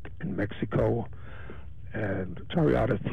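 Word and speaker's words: charreada: So now let's just divert a little bit into how do you pronounce it, Chariada? – That's close. Chariada.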